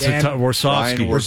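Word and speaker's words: Warsofsky: Warchowski? What is it? – Yeah, it's Warsawski.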